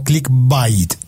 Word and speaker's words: clickbait: ¿Clickbait o clickbait? Eh, ¿Cómo se pronuncia eso? – Hola Félix y equipo de Trenda Week, muchas gracias por la invitación. Bueno Félix, eh, se pronuncia clickbait,